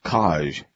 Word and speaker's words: Kaj: Band number four is pronounced Kaj, K-A-J.